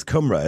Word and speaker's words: Cymru: Yeah, I always pronounce it wrong. It's Cymru, isn't it? – Yeah.